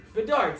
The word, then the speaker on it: Bedard: Bedard.